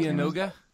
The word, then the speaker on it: Dianoga: Yeah, the Dianagar. – Whatever. – You got the R in there. – Dianoga was like, hey, there's no R there.